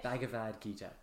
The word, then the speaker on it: Bhagavad Gita: Bhagavad Gita.